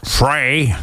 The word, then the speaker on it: Frey: Is voor onze vriend Glenn Frey, en je moet het echt uitspreken op zijn Amerikaans, Frey, Glenn Frey, helaas geen hit geworden.